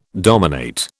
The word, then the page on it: dominate: British English